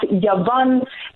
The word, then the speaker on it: Yavan: And you remember, Kath, that we spoke about this, that we said the word Greek in the Hebrew language is pronounced Yavan, and it means murky.